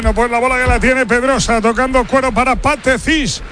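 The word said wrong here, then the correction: Pathé Ciss: ¿Ha visto cómo se dice ahora? Pache Cis. – Sí, lo he entendido, Pache Cis.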